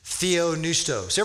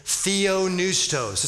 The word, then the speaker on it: theopneustos: He says all scripture is, and here's the Greek word he uses, theopneustos. Everybody say that with me, theopneustos. It's like this, theopneustos.